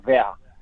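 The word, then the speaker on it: verre: По-моему, оно произносится как «вер». А произносится оно ровно так же, как э, название меха. Да, тоже «вер».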